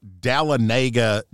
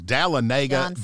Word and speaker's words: Dahlonega: I believe it is Dahlonega, Georgia. – I love it. – That is awesome. Dahlonega, Georgia.